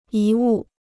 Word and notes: 遗物: yí wù

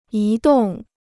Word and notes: 移动: yí dòng

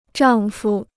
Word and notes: 丈夫: zhàng fu

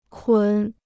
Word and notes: 捆: kǔn

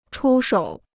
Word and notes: 出手: chū shǒu

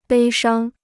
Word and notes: 悲伤: bēi shāng

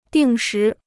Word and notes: 定时: dìng shí

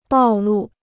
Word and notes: 暴露: bào lù

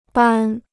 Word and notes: 扳: bān

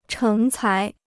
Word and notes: 成才: chéng cái

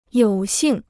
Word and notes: 有幸: yǒu xìng

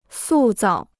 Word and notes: 塑造: sù zào

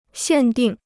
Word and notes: 限定: xiàn dìng

